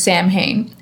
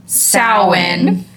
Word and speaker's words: Samhain: So forever, I thought that it was just Samhain. And then when I started doing my research, I was like, this is pronounced Samhain.